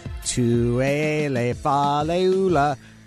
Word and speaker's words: Tuele Faleula: And Neuheisel taught me to pronounce it by singing it, like, Tuele Faleula. There's a rhythm to it.